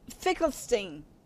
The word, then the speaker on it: Finkelstein: And Finkelstein. Did I say that right? – Yes, yes. Yeah.